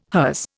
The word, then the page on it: hers: Indian